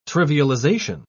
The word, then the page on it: trivialization: British English